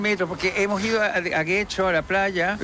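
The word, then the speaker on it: Getxo: Guecho. Yo creo que lo pronunció bien.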